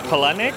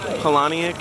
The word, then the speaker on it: Palahniuk: We say, in my family, we say Palenik. And I'm sure that's a, a crude Americanized version, but it's a Ukrainian name, and we say Polonic.